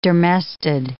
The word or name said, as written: dermestid